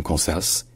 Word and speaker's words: Kansas: Que le S à la fin, il est prononcé dans Kansas, Kansas, Kansas, alors qu'il n'est pas prononcé dans Arkansas. C'est juste Arkansas, Arkansas.